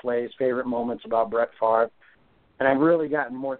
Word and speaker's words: Favre: Speaking of quarterbacks going into the Hall of Fame, there's a guy in 2 weeks, I mentioned him, number four, his name's Favre, that's how it's pronounced, Favre.